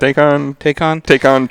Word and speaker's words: Taycan: With my, cars coming on the market like the Porsche Taycan? Is that how you say it? – Taycan Turbo and S, non-turbo.